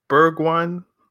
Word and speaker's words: Bergwijn: Double H, what are your thoughts on Bergwijn? Am I pronouncing that correctly? Bergwijn. – You're correct.